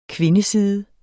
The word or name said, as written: kvindeside